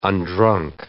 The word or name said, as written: undrunk